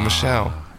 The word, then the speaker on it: Michel: Is it Michael or Michelle? – It's Michelle. I know it's Michelle.